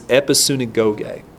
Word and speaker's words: episynagoge: that word in Greek is episynagoge. Spell it E-P-I-S-Y-N-A-G-O-G-E.